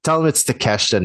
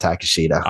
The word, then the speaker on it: Takeshita: But tell him it's Takeshita, not Takashita.